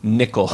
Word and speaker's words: Nicol: I often hear his name mispronounced, but I learned very early on how to pronounce his name. Nicol Williamson. – Nicol.